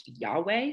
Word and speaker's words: Yahweh: he is referenced to four letters, YHWH, which scholars have pronounced Yahweh.